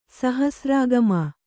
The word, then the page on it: ಸಹಸ್ರಾಗಮ: kannada